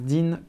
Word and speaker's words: Dean: En fait, ça s'écrit Dean, James Dean comme un dîner.